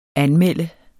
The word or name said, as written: anmelde